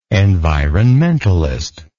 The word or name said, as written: environmentalistic